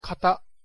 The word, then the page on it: 型: KATA